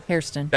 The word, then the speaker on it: Hairston: I mean, we had the best bad news possible for first-round pick Max Harrison. Did I say it right? – Hairston.